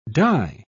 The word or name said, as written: dye